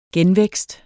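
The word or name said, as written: genvækst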